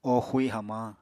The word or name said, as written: o xui hamañ